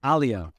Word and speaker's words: Alia: Alia. Okay. – Alia. – Alia. Okay. Yeah. I was wondering if you're not alone in that. We've had a few Americans playing around with the pronunciation of it.